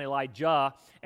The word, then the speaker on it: Elijah: And I'm going to overpronounce the ja and the sha purposely so we can try to tell them two apart. So I'm going to be calling one Elijah and the other one Elisha.